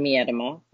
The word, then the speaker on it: Miedema: But the second one I will say is Vivian Miedema.